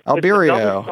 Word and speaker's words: Albireo: It may be Albireo or Albireo.